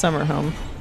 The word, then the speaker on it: Sammo Hung: Home. Pronounced Summer Home.